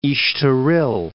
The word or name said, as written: Estoril